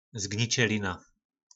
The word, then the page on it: zgnicelina: po nasimu